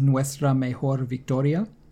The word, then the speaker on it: Nuestra Mejor Victoria: And the hashtag is Nuestra Mejor Victoria, Our Greatest Victory. Did I say that correctly? – See, yeah.